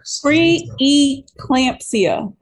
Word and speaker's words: pre-eclampsia: Pre-eclampsia. – There we go. And make sure y'all don't take that out. Keep that in there. Yep, we need that in there. Pre-eclampsia.